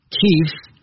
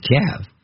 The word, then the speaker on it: Kyiv: Within these last hours, Kyiv, alternate pronunciation Kiev, and NATO countries have expressed concern that Russian troop movements near Ukraine's borders could pave the way for a sharp military escalation.